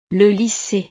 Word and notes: le lycee: frances

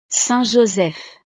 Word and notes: saint-joseph: frances

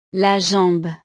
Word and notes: la jambe: French

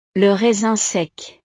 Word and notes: le raisin sec: frances

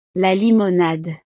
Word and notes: la limonade: French

